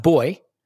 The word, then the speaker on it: buoy: referred to the things that bob up and down in the water that boats go past as a buoy, and was told by Paul that it's actually pronounced boy.